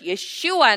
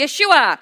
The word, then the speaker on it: Yeshua: And that name is Jesus in English, Yeshua in his original name, his original pronunciation. Say it with me, Yeshua.